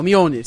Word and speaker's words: Mjolnir: É Mjolnir, não é? – É, é assim Mjolnir, que eu falo. – Mjolnir, Mjolnir, é assim que eu falo, porque o, o é, J tem o é som é de. Assim que pronuncia, né?